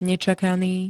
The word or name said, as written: nečakaný